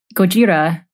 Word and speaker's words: Gojira: No, I, that was the first thing I kind of noticed, Brian, was Gojira. So many through the years, right? English speaking people have made fun of that accent, but it's not. It's actually pronounced differently.